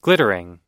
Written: glittering

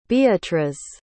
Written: beatriz

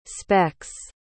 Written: specks

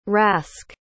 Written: rask

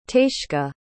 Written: taisce

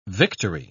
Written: victory

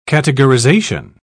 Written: categorization

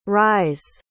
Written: ries

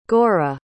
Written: gora

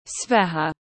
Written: zveha

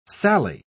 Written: sally

